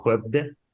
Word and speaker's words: Skövde: Eh, se, pronuncia en sueco Skövde.